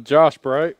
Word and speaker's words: Bright: Josh Bright from South Florida. I'm drinking Bud Light in the can. – In the can. Josh Bright, right? – Yep, Bright. – How do you spell it? – B-R-I-G-H-T.